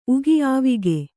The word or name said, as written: ಉಗಿ